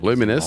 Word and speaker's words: Luminess: On voulait changer de nom et puis finalement, voilà, on a, attendu que tout ça se soit stabilisé et on est devenu Luminaise parce qu'on est à l'étranger ça se prononce euh, plus. – Lumines. – Ouais, euh, lumines, ou euh, dépend comme on a envie de le dire.